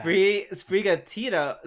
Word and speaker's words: Sprigatito: Sprigatito. Like, Sprigatito.